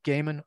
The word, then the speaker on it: Gaiman: Another thing that's interesting, like, you know, the modern fiction, like Neil Gaiman, am I pronouncing that correctly? – Gaiman.